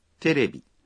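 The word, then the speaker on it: Terebi (テレビ): Por ejemplo, televisión se dice Terebi.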